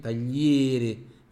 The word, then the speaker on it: tagliere: Questo tagliere di legno, tagliere, attenzione, esse tagliere, fechadinho, tá bom? Fraquinho.